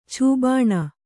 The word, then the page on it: ಛೂಬಾಣ: Kannada; chū bāṇa